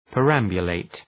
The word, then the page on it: perambulate: pər’æmbjə,leıt